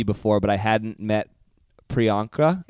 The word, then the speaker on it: Priyanka: It's Priyanka.